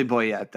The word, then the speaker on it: Boyette: Does he say his name Boyette or Boyetti? – I would say Boyetti. Because Boyetti? That would like because it sounds like Chef Boyardee, but it's definitely Boyette though.